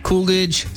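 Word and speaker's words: Koolidge: I am Mike Coolidge, spelled with a K, K-O-O-L-I-D-G-E. Katz is spelled K-A-T-Z.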